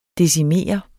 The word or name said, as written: decimere